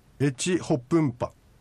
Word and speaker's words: エチホプンパ: これはエチホプンパ、エチホプンパというふうに発音いたします。